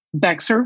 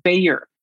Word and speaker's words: Bexar: Bexar County, Texas is pronounced Bayer, like the aspirin.